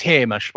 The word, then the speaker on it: Hamish: I don't know if he was ever there at the same time as Roy, but Hotshot Hamish, I believe it's pronounced Hamish, by the way, was in the Tiger.